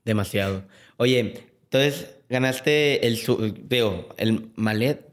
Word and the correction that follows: Maillot: perdón, no sé cómo pronunciarlo. El Maillot, Maillot, Blanco.